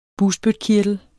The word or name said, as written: bugspytkirtel